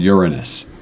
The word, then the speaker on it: Uranus: But the planet is called Uranus.